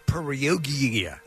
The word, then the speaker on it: Perugia: It's Perugia.